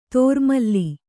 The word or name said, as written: ದುರ್ಮಿಲ